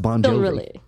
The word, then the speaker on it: Bongiovi: It's the way his last name is actually spelled. Bongiovi. So, but it's still pronounced Bon Jovi.